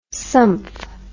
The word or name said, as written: sumph